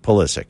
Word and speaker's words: Pulisic: Christian Pulisic, and I know, if Yerk's listening, he's yelling right now, because it should be pronounced Pulisic. or Pulisic, I'm sorry. And but he wants it pronounced Pulisic, so that's what we'll do.